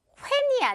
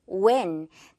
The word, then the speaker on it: when: 예를 들면 when, 언제 이렇게 말할 때도 when이 아니라 당연히 when, 그리고 어디서, where, what, why 이런 다 의문사들이 거의 다 이 WH questions잖아요. 그럴 때 H는 묵음 처리.